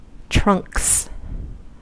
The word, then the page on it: trunks: American English